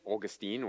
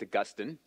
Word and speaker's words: Augustine: Well, Augustine of Hippo, who would become known as St. Augustine or St. Augustine, depending on your pronunciation, he was born in 354 AD in the African town of Thagaste, which is now modern-day Algeria.